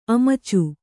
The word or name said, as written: ಅಮುಚ